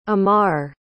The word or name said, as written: ammar